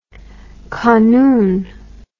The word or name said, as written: kanun